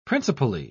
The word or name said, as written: principally